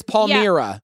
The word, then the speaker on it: Palmyra: I'm so glad I said that right the first time. I've said Palmyra. – Yeah. – I've said Palmyra. It is Palmyra.